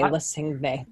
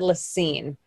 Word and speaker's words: La Cygne: But it's spelled L-A-C-Y-G-N-E, which would lead someone to say Lacigne. But they said Lacine.